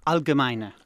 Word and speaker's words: Allgemeine: Allgemeine. – Allgemeine.